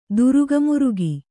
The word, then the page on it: ದುರುಗಮುರುಗಿ: kannada